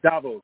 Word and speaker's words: Davos: Is it called Davos or Davos? Do you know how to pronounce that? – Davos. Davos. Okay.